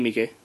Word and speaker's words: Miike: Toshiki Miike. – It looks like Mike with two eyes at the end. And he has... – Yeah, but it's a Japanese name, so it's not pronounced that way.